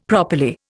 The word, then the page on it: properly: Indian